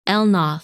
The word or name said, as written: Elnath